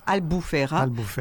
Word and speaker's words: Albufeira: La ville, je ne sais pas comment on la prononce, Albufeira? – Albufeira.